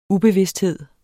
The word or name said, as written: ubevidsthed